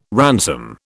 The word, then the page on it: ransom: British English